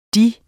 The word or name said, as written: deres